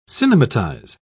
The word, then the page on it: cinematize: British English